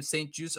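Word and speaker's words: St-Juste: You have Benjamin St. Juice, which I actually looked it up. That is how you pronounce his last name. St. Juice, a third round selection from the 2021 NFL draft.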